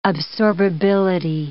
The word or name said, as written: absorbability